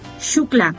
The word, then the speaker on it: Xuclà: Una cartita, entonces, lo pueden hacer a la siguiente dirección postal: calle Xuclà. Se escribe XUCLA, Xuclà.